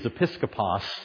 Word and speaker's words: episkopos: It's one of the places where we see, along with Titus, that the Scripture seems to use these terms of overseer, which in the Greek is episkopos, sounds like the word episcopal, because that's what it is.